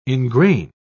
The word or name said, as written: ingrain